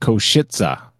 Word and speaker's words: Košice: And apparently that's pronounced Košice. Košice. Košice. I listened to about 500 times, and yeah, it does sound like that.